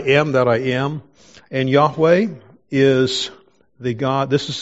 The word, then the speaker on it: Yahweh: Whenever we see that, that's a translation of the Hebrew name of God, which we pronounce Yahweh.